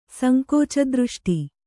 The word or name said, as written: ಸಂಕೋಚದೃಷ್ಟಿ